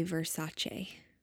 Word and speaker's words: Versace: I like the way you say Versace. – That's how it's pronounced.